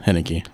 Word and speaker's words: Haneke: So it was Michael Haneke? How do you say it? Is that right? – Yeah, Haneke.